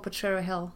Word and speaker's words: Potrero Hill: It's in the beautiful Potrero Hill. Mm-hmm. Did I say it right? – Yeah.